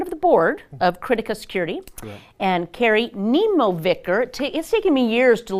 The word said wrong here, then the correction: Nemovicher: Did I do it right? – No. – See? Even then. – It's Nemovicher. – Nemovicher. Okay. Right.